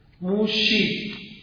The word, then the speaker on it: موشه: موسا رو عربا میگن موسا، عبری میگه موشا، به جا سین شین میگذاره، موشی، موشه دایان که شما میگید مقصود اسم او موساست پیش یهودی ها، منتها موسا، موسا را موشی تلفظ می کنند با شین، و یا شما وقت میگید موشه فکر میکنید مراد موشه، نه موسا.